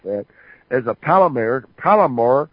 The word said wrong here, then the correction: polymer: chemist. – A polymer chemist.